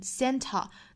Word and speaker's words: Santa: 给省略掉了，然后前面的这个前鼻音跟后面连读 20 当然也是一个非常闪非常弹的一个小小的前鼻音的发音，在美语里面这种发音其实很多见，一般来说是 NT 的字母组合后面遇到元音的时候可以把中间的这个字母 T 给省略掉，然后前面的的发音跟后面元音连读，比如说圣诞老人 Santa，好多美国人在说的很快的时候会把这个词说成 Santa。